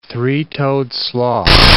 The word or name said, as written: three-toed sloth